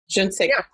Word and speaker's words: je ne sais quoi: And there's the, I'm going to mispronounce it, the je sais ne quoi. – Je ne sais quoi.